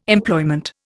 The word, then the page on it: Employment: Indian